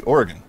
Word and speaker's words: Oregon: Yeah, thanks for making the trip out here, and I'm proud of you because you know most people like back east call it Oregon. So, but you've actually you pronounced it correctly, Oregon. Yeah, Oregon. – Yeah, it's like what you get shot with. – Correct.